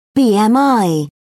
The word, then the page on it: bmi: British English